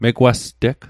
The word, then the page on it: megwasteg: Mi'gmaq